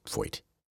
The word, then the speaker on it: Feucht: It's just Foyt. It's spelled F-E-U-C-H-T, Foyt.